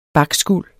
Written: bakskuld

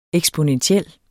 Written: eksponentiel

